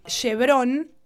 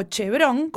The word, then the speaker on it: Chevron: Hoy Texaco es Chevron o Chevron, como quieran pronunciarlo.